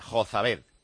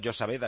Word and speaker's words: Jozabed: Me dice Jerome Asía que Jozabed se suele utilizar en la raza gitana y que se pronuncia como se escribe, Jozabed. Y estamos llamando Josabed aquí como si fuera tal.